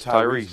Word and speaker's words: Tyrese: I'm going to probably say, you know, and maybe it was a couple, I don't know the order, but Tyree. Am I saying that right, Tyree? – Tyrese Gibson. Yeah.